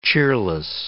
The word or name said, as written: cheerless